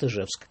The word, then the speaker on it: Ижевск: По крайней мере, в 18-м году ударение было именно там, как мы и говорим. Так что мы немножечко углубились в историю, в том числе и в произношении слова названия города, хотя, конечно, сегодня все говорят Ижевск.